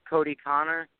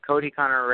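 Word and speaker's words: Kodie Conner: It's at Cody Connor. My racing fan page on Facebook is Cody Connor Racing. And I have an Instagram, and that's at Cody Connor.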